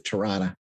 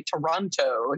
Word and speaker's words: Toronto: So in Manitoba, we pronounce it Tirana. – Tirana? Okay, good to know. – So my husband's from London, and he always laughs when people say Toronto. He's like, Toronto. Just Toronto. It's like two syllables.